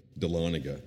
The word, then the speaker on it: Dahlonega: But she tells the story, I always mess up this, I even looked up the pronunciation, so I'll do the best I can. Dahlonega, there it is.